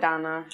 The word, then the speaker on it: Dana: But yeah, it's called Dana, but they pronounce it Donna. You pronounce it like Donna.